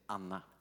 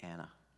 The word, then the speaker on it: Anna: No, we, yeah, but we, well, in Swedish we, we, say Anna. It's a short A. I think that the a, a good English pronunciations would be Anna.